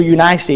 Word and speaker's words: Eunice: To his grandmother Lois and to his mother Eunice, or Eunice, as some pronounce her name.